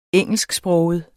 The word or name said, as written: engelsksproget